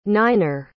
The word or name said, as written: niner